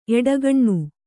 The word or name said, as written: ಎಡಗಣ್